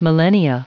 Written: millennia

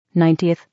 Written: 90th

